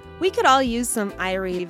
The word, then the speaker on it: Irie: A friendly word you might hear is Irie. Say it like I-ree.